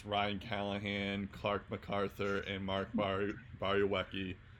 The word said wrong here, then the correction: Borowiecki: I never know. Barokop. Borowiecki, of course, I messed that up. I butchered that pronunciation.